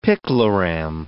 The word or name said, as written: picloram